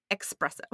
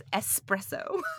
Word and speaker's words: espresso: And in case anybody's wondering, it's espresso.